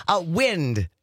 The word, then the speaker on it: wind: Wind, by the way, is the word. Wind. Or wind. – Wind. – W-I-N-D. Spelled the same. W-I-N-D.